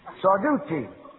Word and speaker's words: Sarducci: Sartusi. Sardusi. Sarducci. Sarducci. You always pronounce an Italian C before an I or an E as a CH.